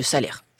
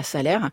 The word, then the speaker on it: Salers: Et puis, on va quand même dans le village de Salers. Salers, faut pas dire Salers. – On dit pas Salers. – On prononce pas le S à la fin, on fait pas son parisien.